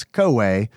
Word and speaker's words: Koei: Anyway, Koei, which I pronounce Koei, which makes the title Koei to Go like way to go, in case you savages have a weird pronunciation, made quite a few SNES games.